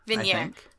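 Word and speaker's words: Vigenère: no, the first letter, the first one is Lar, uh, which, uh, using the vid- hang on, how do you pronounce it? It? Vinier.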